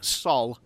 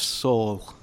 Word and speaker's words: Saul: Can you all tell me why both Jack and Matt so far have called him Sol instead of Saul? Better call Sol. – Yeah, there's the U in there, isn't there?